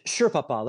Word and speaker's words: Sherpapa: Sherpapa. Like a Sherpa is like a guide, a leader, you know, like Sherpapa.